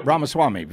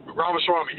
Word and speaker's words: Ramaswamy: Vivek Swami. – Uh, yeah, uh, Ramaswamy, Vivek. – Ramaswamy, yeah.